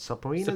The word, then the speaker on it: subpoenas: But basically the story is, Destiny 2 cheat maker Aim Junkies has fired back at Bungie, issuing I can never say this word. Subpoenas. That's the word. – The B is silent.